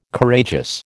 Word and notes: Courageous: American English

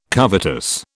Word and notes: covetous: British English

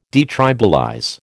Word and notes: detribalize: American English

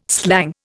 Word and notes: slang: Indian

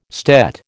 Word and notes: stet: American English

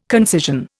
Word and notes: concision: Indian

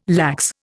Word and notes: lax: Indian

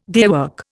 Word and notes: daywork: Indian

